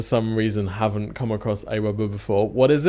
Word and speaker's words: AWeber: So you are from AWeber. For people who don't, am I saying that right? I've always called it AWeber. Is that how you guys say it? – That is. You've got it.